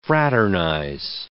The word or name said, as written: fraternize